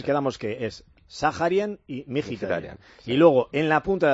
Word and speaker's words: Mkhitaryan: Luego, por dentro, Varela, Aslani y el impronunciable Mkhitaryan. – Igual que Zakarian, la KH es una jota. Armenio, ambos, ¿no? – Mkhitaryan, vale.